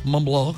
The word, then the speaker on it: Montblanc: 38 marcas reconocidas, entre ellas Armani, Prada, Dolce Gabbana, Miu Miu, Dita, Ray-Ban, Cartier, Gucci, Montblanc. ¿Cuál? Montblanc.